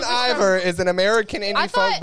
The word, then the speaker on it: Bon Iver: It's pronounced Bon Iver, but it's fine.